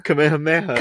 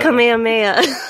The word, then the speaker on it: Kamehameha: Um, I just don't know how to say it because it's like Kamihamiha. – Kamehameha. – Kamehameha. yeah, we're doing good.